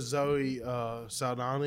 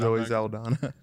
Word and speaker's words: Zoe Saldana: Well, it also has Zoe Saldana in it. – Yeah. – So I think you butchered that. But Zoe Saldana. – There you go.